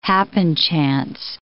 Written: happenchance

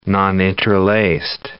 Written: noninterlaced

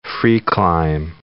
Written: free-climb

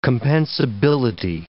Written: compensability

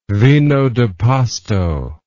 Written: vino de pasto

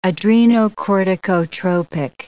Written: adrenocorticotropic

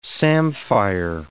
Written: samphire

